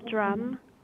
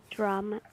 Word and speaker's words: Drumm: Cassandra Drum, C-A-S-S-A-N-D-R-A, Drum, D-R-U-M-M.